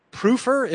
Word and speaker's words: Prüfer: William Yale, an American, Aaron Aronson, a Zionist, and a German spy, Kurt Prüfer. Am I pronouncing that? – Yep, that's right.